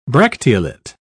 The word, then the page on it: bracteolate: British English